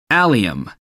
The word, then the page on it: allium: American English